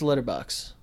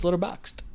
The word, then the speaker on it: Letterboxd: I always forget to pronounce the D. I always think it's Letterboxd. – Oh, okay. – Yeah. – Because that's what the actual framing is called. – Right. – But it's Letterboxd.